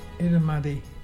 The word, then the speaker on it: Irrumardi: today is Itumadi.